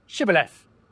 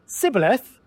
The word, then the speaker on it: Shibboleth: If he replied no, they said, "All right, say Shibboleth." If he said Sibboleth, because he could not pronounce the word correctly, they seized him and killed him at the falls of the Jordan.